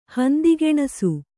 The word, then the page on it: ಹಂದಿಗೆಣಸು: kannada